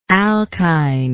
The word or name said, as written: alkine